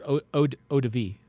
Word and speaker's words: eau de vie: Yeah, I would say eau de vie. I've always pronounced it eau de vie.